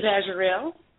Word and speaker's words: Azriel: I was this is Azurel. – Okay. How do you spell that? – A-Z-R-I-E-L.